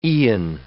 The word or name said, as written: eon